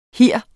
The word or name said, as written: hir